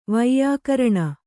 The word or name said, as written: ವ್ಯಾಕೀರ್ಣ